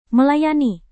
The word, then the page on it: melayani: Indonesian